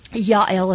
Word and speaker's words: Jael: Yael is the proper way to say her name.